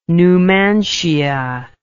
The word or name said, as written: Numantia